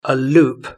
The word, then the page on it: a loop: British English